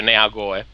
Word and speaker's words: Neagoe: E quindi direi di iniziare subito con una domanda molto generale di Flavio Nigoe, forse è francese, non so come si legga. – Ma semplicemente in italiano si legge Neagoe.